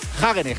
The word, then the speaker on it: Hagenes: Ha ganado un noruego, Per Strand Hagenes, que esto se pronuncia así literal, sí, porque he llamado...